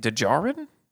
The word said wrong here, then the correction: Desjardins: No. – Desjardins. – Desjardins. I was way off.